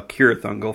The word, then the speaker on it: Cirith Ungol: It's Cirith Ungol, kind of, a lot of people think it's a hard see, you know, Cirith Ungol from the Lord of the Rings, but I've heard that you pronounce it with a soft C.